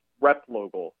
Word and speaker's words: Replogle: It's Replogle.